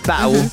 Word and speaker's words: Battle: I pronounced that with a silent T. Battle.